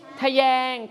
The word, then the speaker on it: ทะแยง: ทะแยงจะไม่มีสระอะหรือไม่ประวิสัญชนีนะครับเวลาออกเสียงต้องออกเสียงอะแบบกึ่งเสียงนั่นเอง